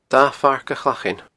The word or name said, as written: Da Phairc a Chlachain